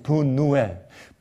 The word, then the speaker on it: 브누엘: 여러분, 그런데 말이죠, 여기 흥미 있는 것은 말이죠, 그런데 원래 이곳 이름은 우리 한국말엔 다 브니엘이라고 기록되어 있지만 그러나 30절과 31절에 있는 그 지명의 그 발음이 달라요. 원래의 원어와 그 다음에 영어로 번역된 데는 30절에 브니엘이라고 말한 야곱의 말은 그 지명 이름이 브니엘이고 원래의 그 지방의 이름은 31절에 브누엘로 되어 있어요.